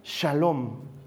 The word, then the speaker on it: shalom: Paz viene de un vocablo hebreo que se pronuncia shalom. Has debido escuchar alguna vez shalom, que literalmente significa bienestar integral.